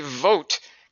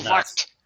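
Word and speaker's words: Vogt: But I think you're actually supposed to say the V. I think you're supposed to pronounce it like vakt. I think you're supposed to like slip it in there like a subtle G.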